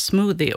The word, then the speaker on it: Smuuti: Det heter smuuti, men det uttalas smoothie och det är finska för det här smoothie som man dricker.